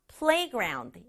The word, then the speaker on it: playground: 앞부분 play에 강조 줘서 playground 이렇게 발음해 주세요.